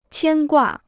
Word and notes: 牵挂: qiān guà

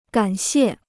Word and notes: 感谢: gǎn xiè

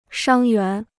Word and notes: 伤员: shāng yuán

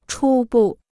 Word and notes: 初步: chū bù

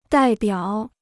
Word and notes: 代表: dài biǎo

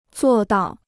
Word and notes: 做到: zuò dào

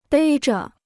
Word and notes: 背着: bèi zhe

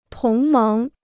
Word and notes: 同盟: tóng méng

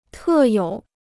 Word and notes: 特有: tè yǒu

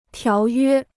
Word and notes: 条约: tiáo yuē

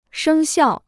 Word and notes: 生效: shēng xiào

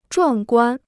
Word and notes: 壮观: zhuàng guān

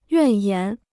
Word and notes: 怨言: yuàn yán

